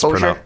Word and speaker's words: Hozier: That song Too Sweet from Hozier, or however you say his name, I always miss Hozier.